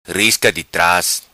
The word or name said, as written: Risca Di Tras